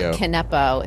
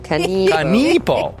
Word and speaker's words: Kanipo: And it's also not Kanepo, if that's how it's spelled. – Yeah, I kept saying Kanepo. – Yeah, every time you said Kanepo, it was like a little dagger. – Kanipo.